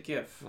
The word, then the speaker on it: GIF: Is it GIF or is it GIF? – I say GIF. I say hard. Guy. I'm a hard G.